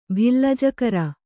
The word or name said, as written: ಭಿಲ್ಲಜಕರ